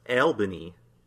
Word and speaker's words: Albany: commonly pronounced Albany. Whoa. Albany? That's what it says. – L-E-L? Albany? – Yeah. – Wow. – That's according. Well, you know what? I stand corrected.